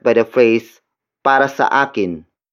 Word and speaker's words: akin: The next word is akin. Akin. Akin. Akin is spelled as A-K-I-N.